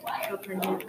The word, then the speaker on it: Calpurnia: To Calpurnia. Is that how you say your name? – Yeah, Calpurnia.